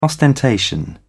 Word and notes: ostentation: British English

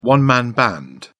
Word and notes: one man band: British English

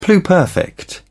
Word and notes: pluperfect: British English

